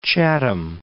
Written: Chatham

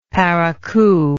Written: parakou

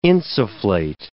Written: insufflate

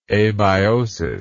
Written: abiosis

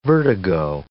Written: vertigo

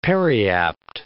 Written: periapt

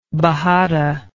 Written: bajada

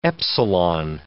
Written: epsilon

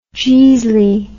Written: jeasly

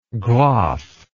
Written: graf

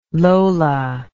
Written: lola

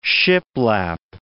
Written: shiplap

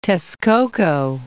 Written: Texcoco

